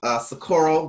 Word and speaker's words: Socorro: This is Reggie Socorro Garner. How may I assist you today? What's your last name? Socorro Garner.